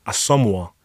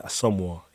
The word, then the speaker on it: Asomugha: Asomugha. – Asomugha. – Yeah, Asomugha, yes.